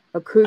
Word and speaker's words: Akugyo: The Aku, how do you pronounce that? Akuguyu, Guyo. I'm not entirely sure. – Akugyo, Akugyo. – Akugyo. Akugyo, got it.